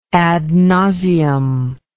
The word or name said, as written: ad nauseam